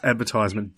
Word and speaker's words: advertisement: It's advertisement, dickhead. You don't advertise it. You advertise it, so it's advertisement.